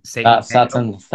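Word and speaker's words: Sátántangó: Satan Tango.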